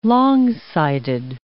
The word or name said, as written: long-sighted